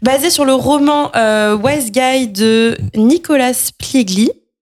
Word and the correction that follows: Pileggi: Pareil, hein, impossible à prononcer ce nom. – Pileggi, ok, qui co le scénario du film avec, euh, avec Scorsese.